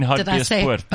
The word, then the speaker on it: Hartbeespoort: You mean Hartbeespoort? – I said it wrong.